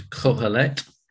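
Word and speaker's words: Qoheleth: Or as the Hebrew says, remember from last time, the word is kohelet, which is spelt with a Q, Q-O-H-E-L-E-T-H, a Qohelet.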